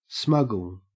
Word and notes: smuggle: Australian English

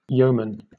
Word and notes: yeoman: British English

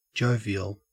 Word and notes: jovial: Australian English

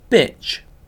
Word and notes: bitch: British English

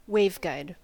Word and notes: waveguide: American English

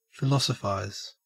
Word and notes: philosophise: Australian English

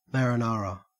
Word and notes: marinara: Australian English

